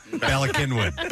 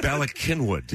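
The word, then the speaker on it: Bala Cynwyd: I don't know how to pronounce the name of the... – Go. Balakinwood. – Balakinwood. – Yes. – Yeah. – How is that wood? – It's Welsh.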